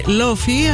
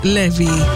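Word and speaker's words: Laufey: Η Ισλανδοκινέζα Λέβη. Γράφεται Λόφι, αλλά προφέρεται Λέβη.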